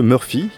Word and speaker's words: Mrfy: c'est que Murphy, qui s'écrit M-R-F-Y, est un tout jeune groupe originaire de la ville de Novo Mesto, à l'est de la Slovénie.